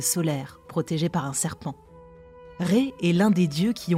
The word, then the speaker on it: Ré: Ré. Ça s'écrit R, E accent circonflexe. On l'appelle parfois Ra aussi.